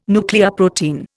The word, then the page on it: nucleoprotein: Indian